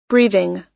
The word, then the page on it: breathing: American English